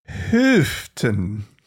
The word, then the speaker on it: Houghton: Well, I'm going to take us to New York, where there's a small Christian university, Houghton University, Houghton, I don't know, H-O-U-G-H. English is hard to pronounce sometimes. I'm going to say, what do you like? Houghton, Houghton? What's your pronunciation on that one? – Houghton. – Okay. I like it. We're going with it. That is the official TGIA pronunciation of that college, of that university.